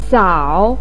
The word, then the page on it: sao3: pinyin